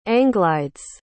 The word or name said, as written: anglides